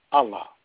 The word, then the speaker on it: Allah: And God, in the Arabic language, in our holy book, the name God is pronounced Allah. Allah.